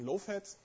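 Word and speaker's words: libowfat: Die Library habe ich vorhin schon erwähnt und vorhin im kleinen Software-Vortrag geschrieben wird sie libofat, aber ausgesprochen lowfat.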